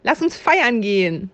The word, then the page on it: feiern gehen: German